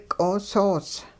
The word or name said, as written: q'oosoos